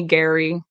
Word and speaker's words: Guary: On any social media platform, I am Brittany Gary, which my name is spelled on here, so it's the G-U-A-R-Y.